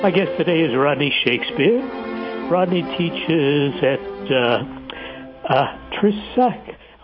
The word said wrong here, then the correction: Trisakti: I can never pronounce that right. Trisakti University. I have to say it slowly.